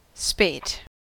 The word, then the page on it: spate: American English